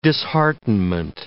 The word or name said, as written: disheartenment